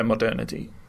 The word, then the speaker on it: modernity: Is that how you say it? Modernity? – Yeah, modernity.